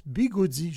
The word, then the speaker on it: bigode: Un mot apparu au 19e siècle qui nous vient du portugais bigode, B-I-G-O-D-E, mais en fait, le portugais, c'est pas facile à parler, le portugais. Ça se prononce bigoudi, justement.